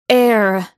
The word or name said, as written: air